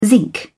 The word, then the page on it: zinc: British English